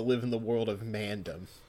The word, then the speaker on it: Mandom: Yeah, Mandom. – Whatever. – It's like Gundam. That's how they say it.